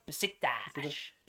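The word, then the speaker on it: Beşiktaş: Besiktas.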